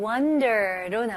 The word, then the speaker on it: wonder: 다음은 wonder로 넘어갈게요. 네, wonder 발음 연습해 볼게요. 여기서는 O이지만 U 발음으로 생각해 보세요. 오케이? Okay? Wonder. Wonder. Wonder는 아니에요. Wonder.